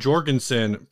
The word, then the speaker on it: Jorgensen: It says, my band Jorgensen, pronounced with a hard J.